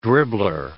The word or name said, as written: dribbler